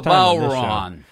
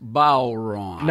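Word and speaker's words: bodhrán: It's called the Bowron. – The Bowron. – But it's spelled Bodhran. So it looks like it's Bodhran, you know. – But it's pronounced Bowron.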